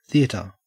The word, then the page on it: theatre: Australian English